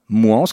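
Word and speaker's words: Mouans: Vous qui m'entendez, tapez bien 1 plus bio, la régie publique alimentaire de Moins, comme ça se prononce, plus loin, Sartou.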